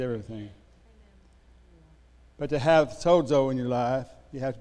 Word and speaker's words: sozo: Make a friend of the word by saying it sozo. It's S O Z O. And, and Z's in Greek are pronounced Z. Sozo. With a D in it.